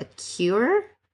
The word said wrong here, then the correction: kuier: Yeah, it's actually pronounced Kair.